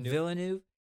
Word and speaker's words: Villeneuve: Villeneuve wasn't nominated for Best Director, which is kind of crazy because the directing in that was great.